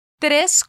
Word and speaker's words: tres: Mas cuidado, porque em espanhol nós vamos sempre falar três. Repita comigo, três. Tres cuadras.